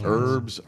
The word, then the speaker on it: herb: It's herb. It's herb. H-E-R-B. – It's silent. Herb.